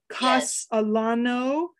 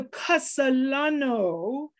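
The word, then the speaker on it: Cacalano: So the C, the second C is not a hard C. It is a soft, is it a soft, it's like the S sound. – Yes, exactly. – So Casalano, Gia, it has been an absolute pleasure to interview you here for anchor.fm.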